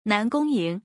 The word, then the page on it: 南宫盈: nan gong ying